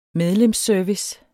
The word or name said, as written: medlemsservice